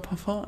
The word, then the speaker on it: Parfum: richtig Parfüm? – Sie sagen immer Parfum. Parfum ist wahrscheinlich die richtige Aussprache, oder? Ja, haben wir uns irgendwie hier alle drauf geeinigt okay.